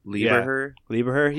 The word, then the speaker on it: Lieberher: How do you say his last name? It's Jaden Liberhair? Liberhair? – Oh, Stutter and Bill? – Lieberher.